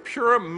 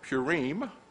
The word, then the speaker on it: Purim: You can pronounce this name in many ways. Some people pronounce it Purim. Among Westerners, it is almost always Purim. Purim, I hope I'm saying that right. Some Central European communities call it Purim.